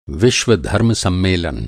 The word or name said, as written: विश्व-धर्मों सम्मेलन